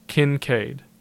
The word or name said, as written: Kincaid